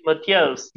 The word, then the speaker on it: Motiejus: And then as far as Krivas, and could you just, is it Motiejus? How do you say his first name? – Yeah, that's, sure. I think Lithuanian is a bit, I would say Matthias, yeah.